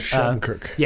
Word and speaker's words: Shattenkirk: But Shattenkirk.